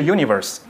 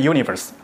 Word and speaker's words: univers: 上面是 universe，它本来是法文应该念成 univers，但是很多人就直接念成 universe 啊，那你看这差别就非常大，它就很舒服，它排成正文就看起来这个就很舒服啊。